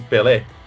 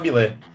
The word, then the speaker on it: Bilé: O, os amigos do Pelé, eles caçoavam com ele, porque na escola ele pronunciava o nome do goleiro Bilé errado, ele, ele pronunciava como se fosse Pelé, ao invés de falar Bilé.